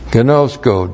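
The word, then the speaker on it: Gnosko (γινώσκω): Gnosko. G-I-N long O-S-K long O. Gnosko.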